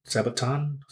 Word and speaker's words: Sabaton: So like, okay, we missed that band, but it was it Testament, Megadeth, Sabaton, I believe that's how you say it. – Sabaton.